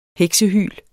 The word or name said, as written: heksehyl